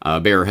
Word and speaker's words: Bayer: It's technically pronounced Bayer, but I'm Canadian, so I'll say Bayer.